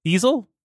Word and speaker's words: easel: So Lucero is a it is a TV on an Ezel Easel. How the fuck you pronounce that word? No, no, Ezel. Is it not bezel? Ezel, easel. It's like the art things, right?